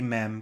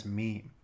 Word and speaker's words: meme: Of course, in French it would be mem because it would be their word for same. But in English parlance in the past few years, it's been pretty consistently pronounced as meme.